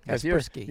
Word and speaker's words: Kaspersky: Is that how you say it? – Yes, that is how you say it. Kaspersky.